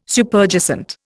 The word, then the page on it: Superjacent: Indian